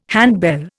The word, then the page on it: handbell: Indian